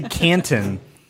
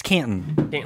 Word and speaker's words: Canton: Well, it's news to me that you say Canton, because we're all from Texas, so I don't say the T. It's Canton.